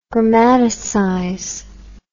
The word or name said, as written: grammaticize